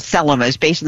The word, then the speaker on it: Thelema: And what she was asking about is something called Thelema. T-H-E-L-E-M-A with the emphasis on the first syllable.